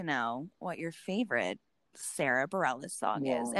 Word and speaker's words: Bareilles: Am I saying her name right? You are now. That's been a battle of years. But Bareilles was where I think you started. Barry Ellis. I mean, you were butchering it.